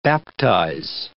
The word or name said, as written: baptize